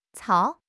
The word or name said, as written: cao3